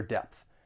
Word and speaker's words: depth: And then just keep in mind depth. It ends with that voiceless TH.